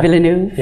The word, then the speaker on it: Villeneuve: but Denis Villeneuve? – Yeah. – Villeneuve? – Yeah.